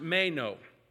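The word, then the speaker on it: meno (μένω): In the Greek, there's a word that you won't see here in your scriptures or up on the screen, but it's pronounced meno. Meno. M-E-N-O. M-E-H-N-O-H phonetically. Meno.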